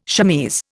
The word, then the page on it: chemise: Indian